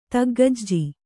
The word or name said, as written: ತಗ್ಗಜ್ಜಿ